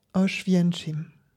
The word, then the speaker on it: Oświęcim: Ce que j'entends pourrait se transcrire phonétiquement, à peu près comme ceci. Oświęcim.